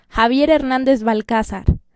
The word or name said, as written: Javier Hernández Balcázar